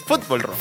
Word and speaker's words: Fútbol Rock: Acento en la U. – ¡Ah! – Es fútbol rock. Fútbol rock.